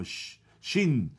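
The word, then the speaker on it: shin (ש): se pronuncia con los hebreos, más o menos lo que entiendo, se pronuncia como SH, como shin,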